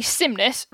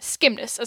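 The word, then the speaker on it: Scymnus: It's actually cymnus rather than skimnus, as I was saying before.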